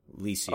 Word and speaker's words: Licia: Is it Licia? – It's like Alicia without the uh. Licia. – all right it's Licia.